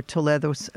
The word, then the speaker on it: Toledo: I did know that the story from the beginning would end in the Sephardic Museum in the city of Toledo, or Toledo in the U.S. people might pronounce it Toledo. So you know what I'm talking about. But the Spanish city is Toledo, and I have been there many times and have been to the Sephardic Museum, which was once a 14th century synagogue.